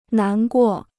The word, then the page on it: 难过: nán guò